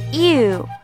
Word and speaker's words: you: see，好，第二个单词 you，读的时候一定不要带儿音哦，不要读成了 your，这是不对的。